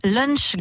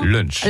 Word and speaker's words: lunch: Il y a lunch gastronomique. – Vous l'avez bien prononcé. – Je l'ai bien dit, hein. – Lunch.